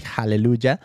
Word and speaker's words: Hallelujah: Y entonces esta chavita tiene un podcast, se llama Aleluya Podcast o Hallelujah en inglés, porque pues la H se pronuncia así, Hallelujah.